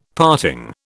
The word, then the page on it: parting: British English